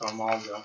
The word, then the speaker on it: Armaldo: Amaldo. – Okay, here it is. – Oh, there it is. – So. – Type. Rock bug. – Okay, and to pronounce his name. So, correct pronunciation is Armaldo.